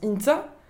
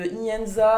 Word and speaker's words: Ihintza: I-H-I-N-T-Z-A, qui se prononce Inta. Alors c'est passé de Inenza à intia Alors déjà, on, on me déplaçait les I aussi, voilà des plein de choses comme ça.